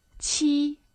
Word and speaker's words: 七: Well, six is liu, fourth tone, liu, and seven is qi, first tone. For example, you can say liu ben shu or qi ben shu.